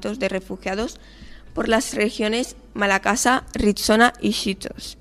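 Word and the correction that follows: Schistos: no sé si lo he dicho bien, y en la propia Atenas. – Hola, muchas gracias. Sí, se dice Esquistos.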